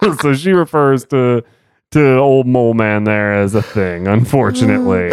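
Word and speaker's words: Moleman: I love that it is always, I think, always pronounced Mole Man and not mm-hmm. Molman. – Mm-hmm. – So it's like Hans Molman, you know, but like Hans Mole Man.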